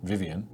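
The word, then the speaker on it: Vivien: I assume that her name is pronounced Vivian. – Yeah. – Yeah. – It's spelled with an E, but... – Yeah, it's a fairly normal name.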